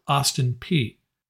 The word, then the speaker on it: Austin Peay: Yes, that is how you pronounce it. I had to look it up. Austin Peay.